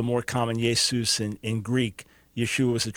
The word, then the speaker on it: Yeshua: Jesus in Greek, and then the spelling in the Peshitta and Syriac indicates that the right pronunciation is Yeshua. There would be a final ah, so Yeshua.